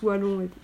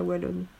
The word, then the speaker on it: Wallon: En fait, Henri Wallon, en fait, hein, ça se prononce Wallon, mais pas Wallon.